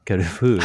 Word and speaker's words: Carrefour: But interestingly, so Carrefour, I'm not sure on the pronunciation of that. – Carrefour.